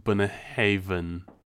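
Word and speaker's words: Bunnahabhain: But it's pronounced Buna Haven. Haven. It's one of those, you know, words where a BH is a V.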